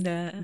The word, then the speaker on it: Bem-vinda: Bem-vinda. Is that the right way to say hello in Portuguese? – Bem-vinda.